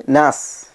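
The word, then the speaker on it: നാസ് (ناس): ഉനാസ് എന്നുള്ളത് നാസ്, അങ്ങനെയാണ് അതിൻ്റെ ഉച്ചാരണം. ഉനാസ് എന്നും പ്രയോഗിക്കപ്പെടും.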